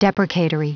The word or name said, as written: deprecatory